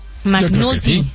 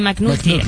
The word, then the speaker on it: McNulty: McNulty. Sí, McNulty.